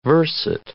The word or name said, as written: verset